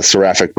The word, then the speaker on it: Seraphic: So, And the album's called Seraphatic Punishment, right? Is that how you say it? – Seraphic Punishment. Yep.